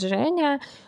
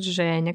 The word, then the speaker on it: Женя: Я говорю, ну да, ну вот на самом деле меня зовут Женя, но вот говорю, тяжело американцам, да, произносить это имя, поэтому я говорю Джейн.